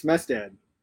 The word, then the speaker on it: Smestad: It's Smestad.